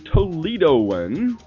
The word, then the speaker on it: Toledoan: A native Toledoan, I guess I'm saying that right.